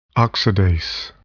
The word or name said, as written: oxidase